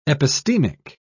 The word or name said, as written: epistemic